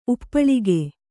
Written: ಉಪ್ಪಲಿಕ

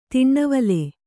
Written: ತೆನೆವಾಲ್

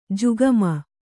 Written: ಜುಗುಮ

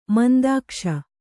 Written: ಮಂದಾಕ್ಷ

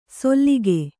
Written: ಸೊಲ್ಲಿಗೆ

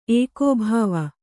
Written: ಏಕೋಭಾವ

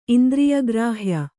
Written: ಇಂದ್ರಿಯಗ್ರಾಹ್ಯ

